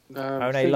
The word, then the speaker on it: Ohno: is it Ohno? – Ohno Larkin.